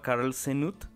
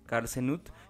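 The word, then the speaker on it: Carll Cneut: Y es un ilustrador belga que se llama Carl Senut. O sea, se escribe C-A-R-L-L y el apellido es C-N-E-U-T, Carl Senut.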